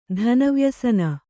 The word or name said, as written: ಧನವ್ಯಸನ